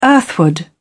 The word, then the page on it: earthward: British English